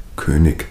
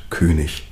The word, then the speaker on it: König: Kleines Beispiel, die berühmte IG-Endung, die ja im süddeutschen Raum gerne hart ausgesprochen wird, also wenig, König, aber nach der Vorgabe, es ist wenig und König.